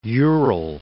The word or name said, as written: Ural